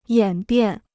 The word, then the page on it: 演变: yǎn biàn